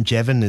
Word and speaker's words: Jevan: Or if I say my full name, Jevin, it's Jevin, Jevon. – Yeah. – Or they spell it J-E-V-I-N instead of J-E-V-A-N.